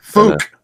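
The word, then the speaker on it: Fouke: Yeah, the Fouk or I don't know. – Fouk. You got to say it like Conor McGregor, the fighter. Who the Fouk is that guy? – Say Fouk.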